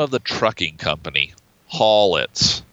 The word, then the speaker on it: Hawletts: It's spelled H A W L E T T S. But yeah, you're right. It's pronounced Hallett's.